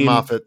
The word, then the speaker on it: Moffat: I worship at the church of Stephen Moffat, and that's how you say his name, by the way. It's Moffat, not great Satan, as I think some like to do.